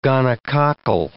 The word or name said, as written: gonococcal